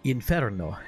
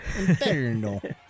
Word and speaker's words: Inferno: Pronounce it like the Italians would. – Inferno. – Inferno.